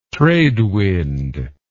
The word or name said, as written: tradewind